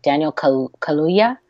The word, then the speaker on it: Kaluuya: Because Not that I can never say his name right. Daniel Kaluuya. – Yeah, perfect.